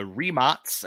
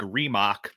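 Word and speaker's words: Rimac: And that is that the Remots, and yes, that is how I've been instructed to say it by some people at the IEVs team. And it might look like Remock, but the proper pronunciation is Remots.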